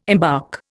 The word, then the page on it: embark: Indian